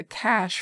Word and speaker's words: cache: You can use either a GPS or just your phone to look for or leave a cache for others to find. Cache sounds like a pile of money, but it's spelled C-A-C-H-E, and it's basically a waterproof container that's camouflaged and usually hidden so that it's not easily visible to someone who's just passing by.